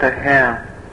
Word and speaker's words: O'Hare: В Чикаго он называется аэропорт O'Hare. – Now this O'Hare, that hare is spelled just like Hare, is Hare Krishna.